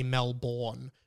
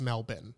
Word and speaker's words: Melbourne: D-I-C-K, I am good at spelling. Tastes so good, I need a second helping. Aren't you glad I know how to say Melbourne? Because like a lot of Americans say Melbourne, but everyone says Melbourne, right, in Australia.